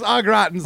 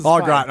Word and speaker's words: au gratin: Tell me if I'm saying this right. Au gratin? Au gratin? – Yeah, just au gratin. – Au gratin.